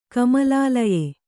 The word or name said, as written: ಕಮಲಾಲಯೆ